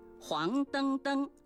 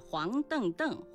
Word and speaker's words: 黄澄澄: 不是的，黄灯灯属于叠词结构，后两个叠字灯灯遵循汉语的语音习惯发生变调，读作音频灯，因此整体注音是黄灯灯，而非原调的黄邓邓或是黄成成。